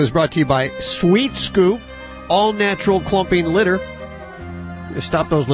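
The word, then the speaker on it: Swheat Scoop: It's spelled S-W-H-E-A-T. Because it has weed in it, so it's Sweet Scoop.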